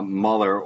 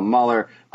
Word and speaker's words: Muller: That, of course, being a big left-handed starter, that would be Kyle Muller or Mueller, depending upon how you like to pronounce his name. I'm going to say it's Kyle Muller.